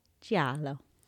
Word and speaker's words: giallo: But here I know him as that dude who taught me how to say giallo, Eli Roth. Hi, Eli. – Hey. – I think I'm still saying that wrong. – You're saying the E. You got to say it like jello.